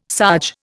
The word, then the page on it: sarge: Indian